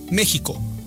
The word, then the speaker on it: México: De hecho, la Real Academia Española reconoce que la pronunciación correcta en español es con el sonido de la J, México, pero recomienda mantener la escritura oficial México con X, por razones históricas, culturales e identitarias.